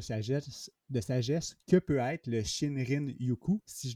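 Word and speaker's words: Shinrin-yoku: Euh, c'est assez bien prononcé. C'est sûr qu'à la japonaise, il y a un petit dring-dring, Shinrin Yuku.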